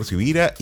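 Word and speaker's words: Sivira: Mi nombre es Carlos Javier Sibira y como estoy claro que mi apellido no es tan común, se escribe con S de semana y con V de Victoria.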